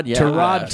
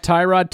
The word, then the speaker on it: Tyrod: Well, one of the guys we're going to talk about through Hard Knocks, we've been pronouncing his name wrong his entire career. Tyrod Taylor. It's not Tyrod Taylor.